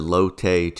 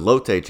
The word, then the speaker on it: Lotte: So last week we had the Lotte Championship. I realized during the broadcast that I had been pronouncing it wrong all last week. I was saying lot.